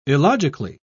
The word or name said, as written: illogically